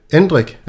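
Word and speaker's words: Endrick: Lad os hoppe videre til Endrik. Er det ikke sådan, det skal udtales, Jesper? Jo, det tror jeg, jo.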